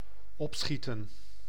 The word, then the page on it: hasten: /ˈheɪs.ən/